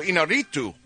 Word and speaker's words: Iñárritu: The Revenant director Alejandro Iñárritu.